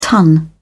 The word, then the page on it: ton: British English